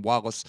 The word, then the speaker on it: Wallis: All of that can be found at William Wallace for America. I always remember the last name is spelled W-A-L-L-I-S.